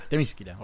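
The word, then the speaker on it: Themyscira: Temiskira, ok.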